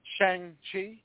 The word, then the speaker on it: Shang-Chi: All right, so yes indeed, I returned to theaters this past week to watch the MCU film, Shang-Chi, if we're going to pronounce it correctly, channeling our best M. Night Shyamalan. Well, like M. Night taught us with The Last Airbender, there are no hard A's in Asian language.